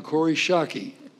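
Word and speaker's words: Kori Schake: On my far left, and just arrived, Dr. Corey Shockey. Did I pronounce that correctly? – Yes!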